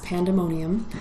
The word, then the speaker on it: pandemonium: But pandemonium, which in the original is spelled with what's called an ash symbol, the A connected to an E see in Greek. But it's still pronounced pandemonium.